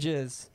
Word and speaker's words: jizz: Star Wars and jizz. That's what it says. – Wait. Jizz. How is it pronounced? How is it written? – Literally like jizz. Like J-I-Z-Z.